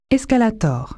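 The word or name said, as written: escalator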